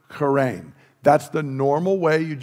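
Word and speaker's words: charein (χαίρειν): We would spell it like C-H-A-R-E-I-N, charein, and it just meant greetings.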